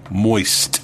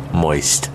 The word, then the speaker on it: moist: Moist. – Why are you emphasizing the T? – Okay, I'll just... Moist. – Yeah. – Moist. – You said it right that time.